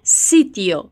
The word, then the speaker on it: sitio: A sílaba ti em espanhol é sempre ti, não é ti, tá bem? Então é sitio.